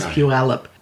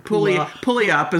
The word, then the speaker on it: Puyallup: The worst one is Puyallup. Puyallup? – I know. Pulley up is what I used to say. – Yeah, I know. – Pulley up.